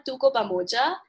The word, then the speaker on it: Tuko Pamoja: Yes, it's called Tuko Pamoja.